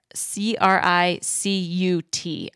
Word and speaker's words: Cricut: C R I C U T. I'm pretty sure that's how you say it.